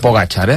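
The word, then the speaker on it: Pogačar: ¿Y Pogacar? – Perfecto. – Pogacar, ¿eh? Ah, perfecto. Pues así, estos dos acertados, dos puntos para mí, dos mini puntos para el Super Sport. ¿Y Roglic o Pogacar?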